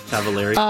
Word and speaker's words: Cavallari: Am I saying that right? – Uh-huh. – Cavallari? – Cavallari.